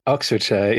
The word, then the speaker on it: Oxfordshire: Oxfordshire, yes.